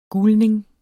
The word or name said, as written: gulning